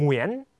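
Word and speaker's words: Nguyễn: เงอเวียนเวียนต่อมาเอามานยุกซักมาใส่ด้วยนะครับเงวียนซักเงวียนเงวียนหุยนเงวียนเงวียนหอยเงวียนเงวียนหงาเงวียน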